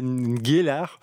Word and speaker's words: Ngelar: Si les gens le cherchent, ça s'appelle Ngelar, N-G-E accent aigu L-A-R.